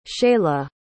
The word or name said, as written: Chaela